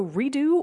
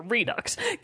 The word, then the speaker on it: Redux: Also on the 28th, we have Metro Redo or Redux. I don't know how to pronounce it. – Oh, it's Redux. – Sure, Redux.